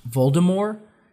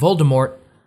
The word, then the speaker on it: Voldemort: But, yeah, for those who don't know, she J.K. Rowling envisioned that his name would be pronounced Voldemort, because she imagined it was like French. So the T is silent. But in the when they did the movies, they called him Voldemort.